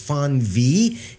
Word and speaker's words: Fenweh: I think that's pronounced Fon V. It is spelled F-E-N-W-E-H.